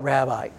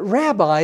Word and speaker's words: rabbi: that rabbi is not the way it's pronounced, really, in Hebrew. It comes from the word rav, R-A-V, you would say it in English.